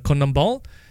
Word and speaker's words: CONMEBOL: So I believe it's pronounced as the Conmebol.